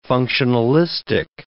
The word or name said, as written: functionalistic